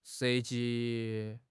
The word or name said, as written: 涉及